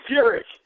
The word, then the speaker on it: Furyk: In Jim, what is it, Furyk? I don't know how to say his name. – Hey, Jimmy Furyk. – Furyk. – Jimmy Furyk. – Too many consonants in that last name.